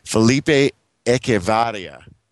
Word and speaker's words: Felipe Echevarria: Felipe, I'm going to pronounce his name wrong. Felipe Echevarria. – That sounds right.